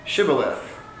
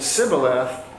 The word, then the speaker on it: Shibboleth: So they said, "Okay, well, please say Shibboleth." If he said Sibboleth, because he could not pronounce it correctly, they seized him and killed him at the fords of the Jordan.